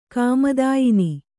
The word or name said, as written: ಕಾಮದಾಯಿನಿ